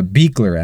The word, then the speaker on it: Buechler: Um, and then uh, the other big name I think to call out here is John Buechler. Is that how you say it? Yeah, Beekler actually is how you pronounce it. Beekler, okay, yeah.